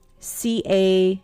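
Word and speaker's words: sidhe: It's S-I-D-H-E, and it's pronounced C-A-Day. It's another one of those words that you're just like, ooh, that's not pronounced any way that it's spelled.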